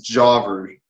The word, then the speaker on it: Javer: It's Javer.